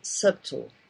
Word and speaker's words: subtle: Yeah, they don't pronounce it. Very subtle. – Yeah. Yes. – We say subtil in our in Portuguese.